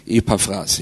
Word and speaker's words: Epaphras: Und der heißt, ich weiß nicht, ob ich das richtig aussprechen kann jetzt, aber er heißt Epaphras. Also nicht wie fressen, sondern mit PH. Epaphras, ja.